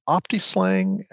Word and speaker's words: OptiSLang: Am I pronouncing it right? I can't. Is it? It's OptiSLang. That's how they say it. – Yeah, that's the way I've always heard it.